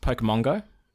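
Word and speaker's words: Pokémon Go: I believe it's pronounced Pokemon Go.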